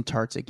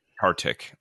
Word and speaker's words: Tartick: And is that how you say his name? – Caitlin Bristow and Jason Tartick, yeah. – Tartick. All right.